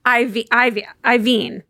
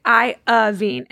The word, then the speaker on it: Iovine: Because he'd say Iovine. Mm-hmm. And we say Iovine. And one's two syllables and one's three.